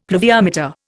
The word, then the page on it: pluviometer: Indian